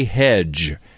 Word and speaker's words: Hedgeh: Hedge, and his name is spelled H-E-D-G-E-H, right? – Yep. – And obviously the H, last H, silent.